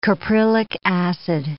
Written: caprylic acid

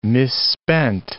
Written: misspent

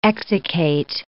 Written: exsiccate

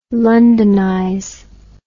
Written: londonize

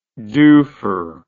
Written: dufer

